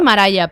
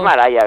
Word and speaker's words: Mariah: Sí, yo creo que es Mariah, pero... – Es Mariah, ¿verdad? – Yo creo que sí, pero bueno, da igual, María para nosotros.